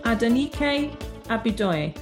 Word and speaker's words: Adenike Abidoye: Hi everyone, today I'm joined by Adenike Abidoye.